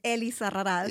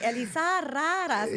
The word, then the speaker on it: Elizarraras: I'm like, Elisa Raraz. And yeah. A lot of people can't roll their R's.